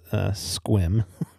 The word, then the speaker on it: Sequim: Squim, you pronounce it Squim. It looks like Sequim. but it's Squim.